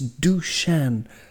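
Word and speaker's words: Dušan: But his first name is, it looks like Dusan, but the S has a little V on top, and that changes the S to an SH. So it's Dusan Vlahovic, and that was absolutely marvellous pronunciation.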